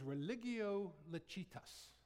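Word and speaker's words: religio licita: Um, it was religio lecitas. That's the way you pronounce it in Latin, I'm told.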